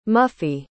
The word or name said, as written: muffy